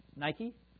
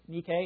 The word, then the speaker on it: Nike: Victory in Greek is, you might know, be familiar with it, it's Nike, or Nike if you want to pronounce it that way.